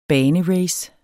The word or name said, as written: banerace